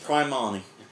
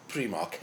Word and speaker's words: Primark: Finest Primarni. – It's pronounced Primark.